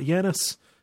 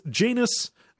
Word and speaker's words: Janus: That's how they pronounce it. It's not Janus. You know, it's not like the Greek god. It's Janus, like anus, is how they say it.